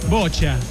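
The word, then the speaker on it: boccia: En la Teletón contamos también con atletas en natación, atletismo, bocia. Yeah, ¿cómo se pronuncia esto, hijo? – Bocha.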